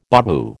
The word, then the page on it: babu: American English